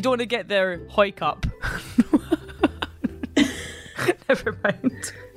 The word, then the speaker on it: Howick: Now, some people pronounce it Howick, but a quick Google has taught me that people from Hoik tend to call it Hoik.